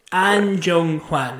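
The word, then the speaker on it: Ahn Jung-hwan: A-H-N. – What are you doing? No, that's. – An Jung Hwan is how you say it.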